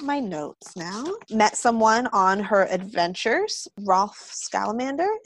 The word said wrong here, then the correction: Scamander: Am I pronouncing that last name right? – Rolf Scamander. Scamander. Grandson of Newt Scamander.